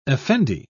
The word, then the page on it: effendi: British English